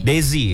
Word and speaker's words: Desi: Ini bacanya Desi atau Dizi atau apa bacanya nih? – Desi ya.